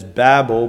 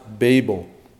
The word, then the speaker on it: Babel: Over the years, we've always said it as Babel, but if you look in your Bible, if your Bible has pronunciations and the little lines and things that tell you how to pronounce it, it should be pronounced Babel.